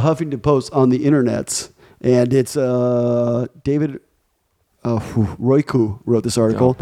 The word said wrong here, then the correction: Royko: How would you pronounce that? – David Royko. – Royko. Okay, what did I say? Royku.